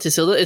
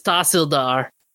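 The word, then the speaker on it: Tehsildar: oh, it's uh, it's not Tisilda, it's Tarsildar. That's the pronounce, uh, pronunciation.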